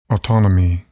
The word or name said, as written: autonomy